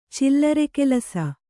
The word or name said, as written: ಚಿಲ್ಲರೆಕೆಲಸ